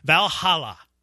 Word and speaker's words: Valhalla: The PGA Championship at Valhalla. Is that how it's pronounced? – Valhalla.